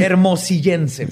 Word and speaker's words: hermosillense: Este crimen estremeció a la comunidad hermollicense al grado de que encontré una anécdota del escritor Alberto Pérez Nájera. – Espérate, ¿es hermollicense? – Se dice así. – Hermosillense, perdón.